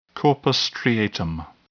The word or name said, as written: corpus striatum